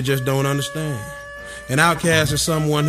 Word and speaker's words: Outkast: Outcast, pronounced outcast.